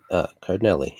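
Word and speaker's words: Cardellini: Cardinelli, yeah. Cardinelli. It's phonetic, Andrew.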